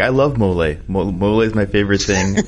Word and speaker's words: mole: It was like a rice and something with like mole. Isn't that how you say it? Mole? – Mm-hmm.